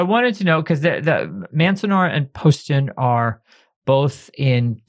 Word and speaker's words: Manzanar: And you focus in particular on Manzanar and Poston.